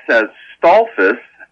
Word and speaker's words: Stolfus: Yes, Stolfus, S-T-O-L-F-U-S, evidently is from German,